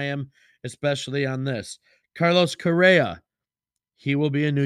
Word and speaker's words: Correa: Carlos Correa.